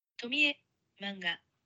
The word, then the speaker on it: Tomie: I'm showing you the Japanese pronunciation of Tomie. Here it is. Ready? – Okay. – Tomie manga.